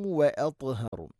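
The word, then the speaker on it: وأطهر: خير لكم واطهر واط ط എന്ന ഉച്ചാരണം ശ്രദ്ധിക്കണം. ഇത്ബാഖ് എന്ന സിഫത്ത് നിർബന്ധമായിട്ട് കൊണ്ടുവന്നാലാണ് ആ ഒരു ശബ്ദം ക്ലിയർ ആവുകയുള്ളൂ.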